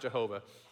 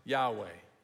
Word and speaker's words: Yahweh: It's not Jehovah. It's pronounced Yahweh.